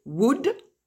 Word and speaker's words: would: La branche symétrique de can, c'est could, C-O-U-L-D. La branche symétrique de will, c'est would, W-O-U-L-D. Je rappelle encore, le L ne se prononce jamais. Et le symétrique de shall, c'est should, S-H-O-U-L-D. Le symétrique de may, c'est might, M-I-G-H-T.